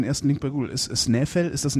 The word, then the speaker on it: Snæfell: Ja, ja, dieser, dieser AE-Buchstabe, den es im Isländischen, ich glaube auch im Schwedischen, Norwegischen gibt, wird hier halt wie Ei ausgesprochen. Ei, also Snæfell.